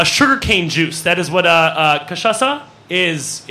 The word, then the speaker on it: cachaça: It is a cachaça, which is a Brazilian type of liqueur.